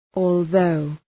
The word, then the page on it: although: ɔ:l’ðəʋ